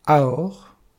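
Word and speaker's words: aor (עור): La peau, la tunique de peau qui recouvre l'homme, c'est un aïn, un vav et un resh. Prononcé « aor », c'est la tunique de peau, la peau. Prononcé « hiver », ce sont les mêmes lettres. Prononcé « hiver », cela signifie la cécité, être aveugle.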